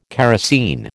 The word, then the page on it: kerosine: American English